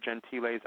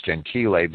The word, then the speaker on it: Gentile: Now, let me just say as a side note that you're pronouncing Giovanni's last name as Gentile, but it is Gentile.